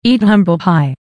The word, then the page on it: eat humble pie: American English